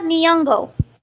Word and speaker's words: Nyong'o: Nyong'o. Huh? – Lupita Nyong'o.